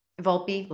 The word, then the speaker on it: Volpe: Anthony Volpe, is that how you say it? Anthony Volpe? – Volpe, love that even more than Volpe, moved up.